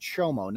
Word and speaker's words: chomo: It's pronounced Chomo, not Como.